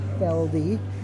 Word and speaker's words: Felde: Barbara Feldy, F-E-L-D-E. – Can you spell your first name? – B-A-R-B-A-R-A.